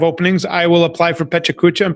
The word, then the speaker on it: Pecha Kucha: He's like, you know, you compress it to like 15 minutes, or even the I'm going to mispronounce it again, the Pecha Kucha. Did I nail it? – Oh, you said it right.